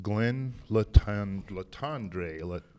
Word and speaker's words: Glenn Latendre: And right now, we have come to the Syngenta booth, and I am going to visit with Glenn Latendre. Did I say that right? – It's Glenn Latend, yes.